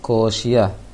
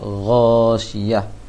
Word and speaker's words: Ghasyiyah: Tadi baca ko, ko, ko syiah, seharusnya gho syiah. Agho, agho, gho syiah, bukan ko syiah.